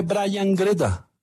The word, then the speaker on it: Gruda: No se pierdan el gol de Brian Greta. Pues se escribe Gruda, pero.